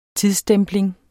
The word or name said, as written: tidsstempling